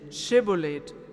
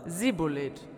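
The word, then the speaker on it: Schibboleth: Ließen sie ihn sprechen. Schibolet. Sprach er aber, Sibolet weil er es nicht richtig aussprechen konnte.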